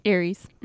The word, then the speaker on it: Aries: Aries. – Mm-hmm. Is it Aries? – Yes.